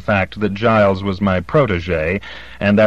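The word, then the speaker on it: protégé: "Wasn't Giles DeVore your protege?" He pronounced the last word with an English soft G and with a long E at the end.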